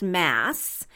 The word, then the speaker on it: Mads: And like I said, the D is silent, so his name is pronounced Mass.